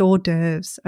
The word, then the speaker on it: hors d'oeuvres: I didn't know how to pronounce hors d'oeuvres. I used to say hors d'oeuvres for a very long, possibly awkwardly long time until I realized I put two and two together. It's a French word. It's pronounced hors d'oeuvres, okay?